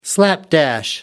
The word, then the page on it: slapdash: American English